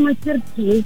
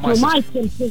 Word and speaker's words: мастерски: Скажите, пожалуйста, Марина, как вы говорите, мастерски или мастерски? Все-таки, куда поставить ударение правильно? – Ну, вообще, я говорю мастерски на последнее из. – Так, это вы просто запомнили, или у вас какое-то проверочное слово, может быть, есть? – Да нет, просто как бы само звучание слова, произношение. – А мастерски как-то оно не звучит. Во всяком случае, мне лично как бы режет ухо.